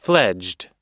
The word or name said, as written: fledged